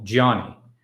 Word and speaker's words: Gianni: Gianni is how you say my name. Gianni. Put the emphasis on the last syllable. Gianni. I still try to do it on the first.